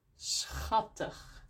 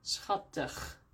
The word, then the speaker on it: schattig: Maar soms hebben we CH, zo CH, combinatie, die we ook uitspreken als een G. Bijvoorbeeld, for example, in school. S-C-H, school. Of schattig, cute. S-C-H, schattig.